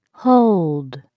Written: hold

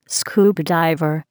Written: scuba diver

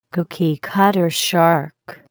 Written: cookiecutter shark

